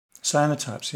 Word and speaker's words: cyanotypes: Her end of year for college has been all like cyanotropes. Is that how you say it? Cyanotropes. – Cyanotypes, yeah.